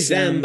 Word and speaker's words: xem: Yeah, Xem. – It's basically Xem, but instead of a TH, you have an X. – Yeah. – For Xem self. I think that's how you say it. – Yep. – And then there's Zizim, Z-I-E-Z-I-M, which, see, and then there's like 60 of these.